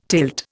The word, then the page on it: tilt: Indian